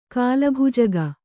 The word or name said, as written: ಕಾಲಭುಜಗ